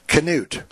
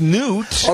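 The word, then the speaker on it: Knute: You know, I told you it's Newt. – Okay, well, okay, so I'm going back. – You remember the football coach, Newt Rockne? – Sure, well, it wasn't Canute Rockne.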